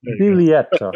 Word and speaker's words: Biglietto: the Biglietto speech or Biglietto speech, how do you say it in Italian? Biglietto.